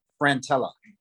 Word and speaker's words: Frantella: Stacy, how do you pronounce your last name? – Frantella. – That's what okay. I thought. Cool. I got it right. Frantella. Yeah. – Three syllables, one L.